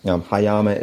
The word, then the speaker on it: Hyama: The residency that you mentioned, you did get the pronunciation correct. It's Hyama Artist Residency. Hyama is a small town on the Pacific coast in Japan, which is where I live now.